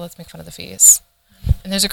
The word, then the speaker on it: FIS: But the acronym is the FIS, le FIS, which is how you say it in French, and that's the same, it's a homonym for the word son.